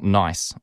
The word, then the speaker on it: Nice: And the funny thing about a Nice Biscuit, and Nice is actually pronounced like the name of the city in France, not nice, although when you read it, you wouldn't know that. You'd say it's a nice biscuit. But of course, you know, saying something is a nice biscuit is different from saying that it is a nice biscuit. So it's actually correctly pronounced Nice.